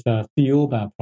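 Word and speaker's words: Thiaoouba: Well, the author, Michel Desmarquet, pronounces as Theoba Prophecy. – The Theoba Prophecy, okay. – Which is similar to the pronunciation of Jehovah, which has a connection to the Bible.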